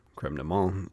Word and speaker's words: crème de menthe: Montpastil is the original crème de menthe, or crème de menthe, if you pronounce it the French way.